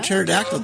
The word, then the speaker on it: pterodactyl: Why can't you hear a pterodactyl go to the bathroom? – I don't know. – Because the P is silent.